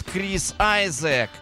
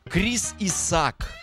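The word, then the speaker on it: Chris Isaak: Ну, может быть, вот мне что-то подсказывает, что многие его почему-то будут знать у себя в голове как Крис Исаак. Раньше 100% так произносили. Ну, в какой-то момент он стал Айзеком. Ну и окей, и хорошо.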